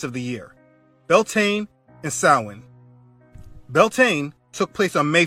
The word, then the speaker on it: Samhain: Now, the word doesn't look how it's pronounced. Samhain is a night celebrating death and hell.